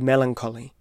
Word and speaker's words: melancholy: And I don't mean to make fun of my former lover when I recall the curious way that she pronounced the word melancholy, with the emphasis on the second syllable. To this day, that pronunciation comes into my mind at frequent but unexpected intervals, in the ghost of her voice, an echo throughout the years. Melancholy. Perhaps I simply prefer it to the usual pronunciation, in which we emphasize only the first syllable and then rush through the rest of them dismissively.